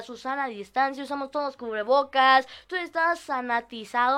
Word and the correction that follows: sanitizado: Sanitizado, seguro que es sanitizado. Yo lo había escuchado como sanatizado en un noticiero.